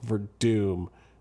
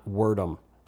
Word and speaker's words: Werdum: And yes, it's pronounced Werdum, not Werdum. I've heard people on the radio and like non-MMA people calling him Werdum all week.